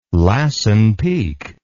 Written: Lassen Peak

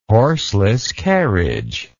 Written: horseless carriage

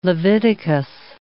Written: Leviticus

